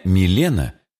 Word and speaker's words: Милена: В чешском языке имя «Милена» произносится с ударением на первом слоге.